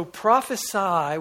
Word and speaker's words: prophesy: So prophesy with an S, with a long I sound at the end, that's a verb, and it basically means to give a message received from the Holy Spirit.